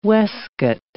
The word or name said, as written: waistcoat